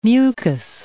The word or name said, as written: mucus